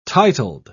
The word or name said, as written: titled